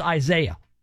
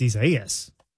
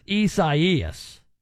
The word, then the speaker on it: Isaias: He says it's pronounced Isaiah. It's not actually. – In Spanish, it's Isaias. – It's Isaias. It's not Isaiah, Mike says. Mike might want to pronounce it that way. That's fine. But I've confirmed it is Isaias.